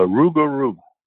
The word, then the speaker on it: Rougarou: A Rougarou.